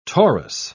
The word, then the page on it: torus: British English